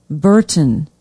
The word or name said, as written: burton